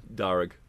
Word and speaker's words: Darug: Darug.